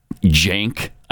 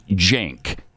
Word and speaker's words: Cenk: But it's so bad now that Sink, you know, the guy from Young Turks, he actually pronounces it Jank, I guess. C-E-N-K, Jank.